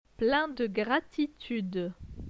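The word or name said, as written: plein de gratitude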